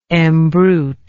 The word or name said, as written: embrute